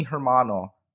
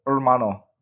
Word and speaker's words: hermano: Like, it sounds like you are mi hermano. You know, you are just, you are there. – Don't pronounce H. – Hermano. – Hermano.